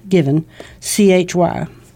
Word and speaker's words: Givenchy: G-I-V-E-N, given, C-H-Y. Mm. Givenchy. – Mm-hmm. – Last syllable emphasized.